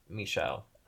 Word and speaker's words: Michel: So, already one name change from Michel. – Okay. – Which also, it's spelled like Michelle, but it's pronounced Michel. Yeah.